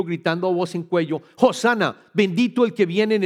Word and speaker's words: Hosanna: y se debe pronunciar como Hosanna.